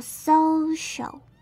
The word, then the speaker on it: social: 第一个是 social 这个字，social 中的 c 它的发音发作 sh，所以整个字念作 social。